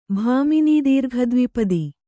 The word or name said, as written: ಭಾಮಿನೀದೀರ್ಘದ್ವಿಪದಿ